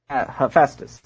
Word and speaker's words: Hephaestus: Who is Hephaestus? I don't know how to say his name. – Hephaestus is, yeah, well, we'll accept that.